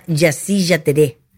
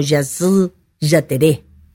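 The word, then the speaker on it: Jasy Jaterê: Este cuarto hijo, digo, se llama Yassi Yateré. Algunos lo nombran con más gutural la pronunciación, ¿no? Algo así como Yazú Yateré.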